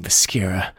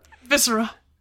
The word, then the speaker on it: viscera: A throne sat in the center, a discolored mound of soft bones and melting viscera. That's not how you pronounce that, probably. Viscera? – Viscera.